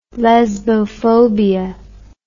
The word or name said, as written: lesbophobia